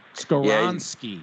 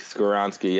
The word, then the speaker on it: Skoronski: Skoronski. Yeah, Skoronski. Yep, that's how you pronounce that, I think.